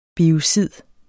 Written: biocid

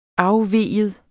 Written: afvig

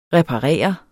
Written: reparere

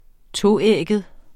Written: toægget

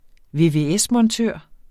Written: vvs-montør

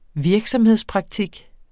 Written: virksomhedspraktik

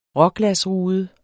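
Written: RÅGLASRUDE

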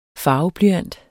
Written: farveblyant

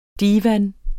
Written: divan

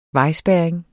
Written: vejspærring

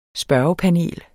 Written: spørgepanel

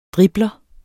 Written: dribler

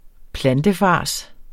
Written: plantefars

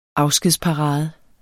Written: afskedsparade

